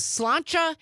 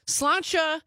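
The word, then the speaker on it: sláinte: Amy from Madison texting in on the text line saying the correct pronunciation of the Irish cheers to your good health is slancha. That's how I've always pronounced it. So slancha.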